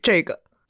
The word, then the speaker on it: 这个: So Z-H-E, in your textbook it says 这个, mm-hmm. – But in Beijing... – They say 这个. – 这个, it almost becomes a, what's that, a diphthong, where you have two vowel sounds mm-hmm. stuck together. 这个.